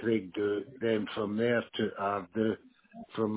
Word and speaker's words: Ardu: A-R-D-U, Ardu.